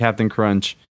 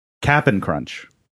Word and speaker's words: Cap'n Crunch: But I also haven't had Captain Crunch. – Cap'n Crunch. – Please. – pronounce it right.